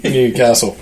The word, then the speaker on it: Newcastle: You have to get your website out, your pronunciation website. Yeah, so yeah, they say Newcastle.